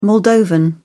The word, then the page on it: moldovan: British English